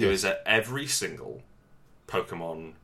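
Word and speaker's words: Pokémon: Is that how it's pronounced? Pokemon. Yes. – Pokemon. Little fellas in the balls.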